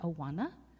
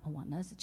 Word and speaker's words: Awana: We've taught Sunday school, youth group, worked in Awana together, and that is pronounced Awana, not Awanas, just in case anyone needs clarification. – Thank you. – He might shudder if you say Awanas, by the way.